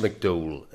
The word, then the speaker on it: McDowell: And it would be pronounced McDowell in Northern Ireland.